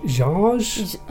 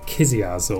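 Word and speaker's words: Książ: To, yeah, well, it's spelt like K-S-I-A-Z. – Oh, I have no idea. – But I looked up the pronunciation. Apparently it's sort of Jarge. – Okay. – But it just looks like it should be called Kiziaz or I don't know.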